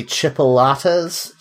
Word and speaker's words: chipolatas: I'm trying to make specifically chipolatas. Chipolatas? Yes, is that how you pronounce that? – That's correct.